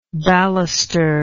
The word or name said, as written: balustered